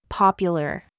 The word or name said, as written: SCHWA